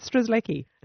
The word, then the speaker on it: Strzelecki: So how do you say Strzelecki? – Paweł Edmund Strzelecki.